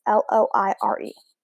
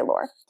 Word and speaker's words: Loire: In this corner of the Loire Valley, I also don't have to, that's how you say it. It's Loire. I'm going to say Loire.